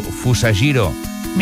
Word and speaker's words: Fusajiro Yamauchi: Sí, el creador es diu Fusajiro Yamauchi. – Mira que bé ho pronuncies. – Fusajiro Yamauchi, aquest senyor, aquest senyor i tota la seva família estan forradíssims.